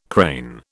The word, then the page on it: crane: British English